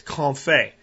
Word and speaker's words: confit: It's confit. And if you want to look up information on it, it's spelled French, so it doesn't sound like it's spelled in English phonetically. It's C-O-N-F-I-T, like confit is how you would phonetically pronounce it, but confit.